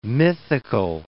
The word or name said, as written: mythical